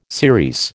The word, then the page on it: series: American English